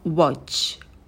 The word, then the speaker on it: watch: Следующее слово – это watch, watch. На «в» вытягиваем губы, потом краткий «о» и звук «ч». Язык стремится наверх.